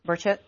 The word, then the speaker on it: Burchett: Representative Tim, I think it's pronounced Burchett or Burchett from Tennessee. – Burchett. Yep.